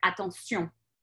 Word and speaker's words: attention: It's just the pronunciation differs, because you accentuate the last syllable in the French language. Like the word attention in English would be attention, versus in French, same word, attention. And then you're putting the emphasis on the end.